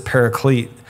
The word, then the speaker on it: paraclete: Um, there's another para word that comes up a lot, and that's parakletos. We, we pronounce it paraclete, but it, it means alongside para.